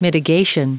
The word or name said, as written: mitigation